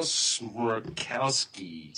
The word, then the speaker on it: Smrokowski: This is Daniel Smirkowski.